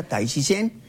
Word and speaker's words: Tai Chi Quan: El nombre completo es Tai, bueno, se escribe Tai Chi Quan, se pronuncia Tai Chi Chen.